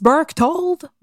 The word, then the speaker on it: Berchtold: Il s'agit de Eloise Burktold. J'ai bien de la misère à prononcer son nom, je vais vraiment en faire comme de mon mieux. Mais c'est une, une américaine en plus, ah, c'est Eloise Berktold.